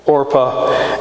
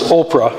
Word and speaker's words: Orpah: They marry Moabite women, Orpah and Ruth. And people often pronounce them as Oprah and Ruth.